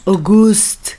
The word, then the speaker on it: Auguste: En francés sería Auguste, ¿o cómo se pronuncia? Auguste. Auguste.